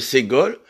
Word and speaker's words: ségol: Il y a le « ségol » qui se prononce « et », mais c'est trois points, deux points et un point en dessous, comme un triangle.